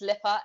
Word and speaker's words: Lipa: It's Lipa, LIPA underscore Nessa.